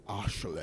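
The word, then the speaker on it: Ashleigh: It's almost like, imagine if we actually pronounced the people that call themselves Ashley, but it's A S H L E I G H. Like, imagine you had to actually say that. – Pronounce like Ashley.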